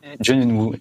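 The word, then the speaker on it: Jo Yeon-woo (조현우): Jo Yeon-woo, si je le prononce bien, pardon. – Jo Yeon-woo, exactement, ouais. Tout à fait, c'est ça.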